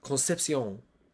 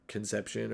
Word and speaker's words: Concepcion: Their leading receiver is a true freshman, Kevin Concepcion. I'm pretty sure that's not how you say it. I'm pretty sure it's like Conception or Concepcion without like super italics and accents.